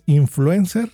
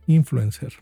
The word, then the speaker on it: influencer: No es influencer, se debe de pronunciar influencer, me acabo de enterar.